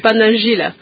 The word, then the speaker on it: Pandanzila: Donc un Pandanzila, je prononce bien, Pandanzila, encore Pandanzila. – Oui, c'est celui qui urbanise, oui.